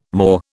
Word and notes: maw: British English

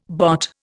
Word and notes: bot: Indian

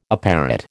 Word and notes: apparat: American English

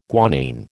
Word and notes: guanine: American English

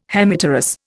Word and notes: Hemipterous: Indian

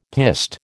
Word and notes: piste: American English

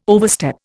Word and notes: overstep: Indian

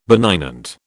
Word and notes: benignant: British English

